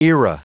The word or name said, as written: era